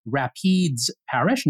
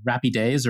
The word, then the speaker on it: Rapides Parish: And thanks to the commenters who corrected me, it is Rapides Parish, not Rapides or whatever three-syllable thing I came up with last pod.